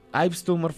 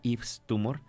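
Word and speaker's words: Yves Tumor: Eso que acabamos de escuchar es parte de Heaven to a Tortured Mind, Ives Tumor, o Ives Tumor, supongo que se pronuncia Ives Tumor, como Ives Saint Laurent.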